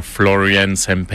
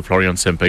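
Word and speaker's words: Florian Sempey: C'est quelque chose qui ressemble à Florian Sempe. Il prononce toutes les lettres, donc on est proche de Florian Sempe, mais on se comprend bien.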